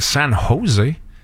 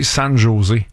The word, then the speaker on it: San Jose: Puis un de nous deux, au lieu de dire San Jose, qui est la prononciation parfaite, un de nous deux aurait dit San Jose.